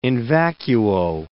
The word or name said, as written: in vacuo